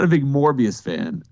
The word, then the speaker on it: Morbius: and I'm not a big Mobius guy. Morbius, thank you.